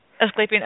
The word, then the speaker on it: Asclepian: I wanted to ask you about this, and I don't know if I'm going to pronounce this correctly or not, but you write about the Asclepian, is that how you say it? Healing dream temples? – Yes, I guess, or Edward Tick writes about that. Did I say that right? – I believe so, yeah.